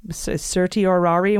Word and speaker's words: certiorari: Certiorari is certiorari. When I remember that, it's pronounced certiorari.